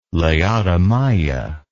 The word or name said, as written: laodamia